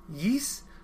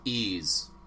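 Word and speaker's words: Ys: Yeez, I believe is how you say it. Origin? – Yeez. – Yeez? – It's called Ease. – Ease. Oh, okay. That's weird. The game is spelled like that. – I know, it's kind of a weird pronunciation of it, but it's pronounced Ease.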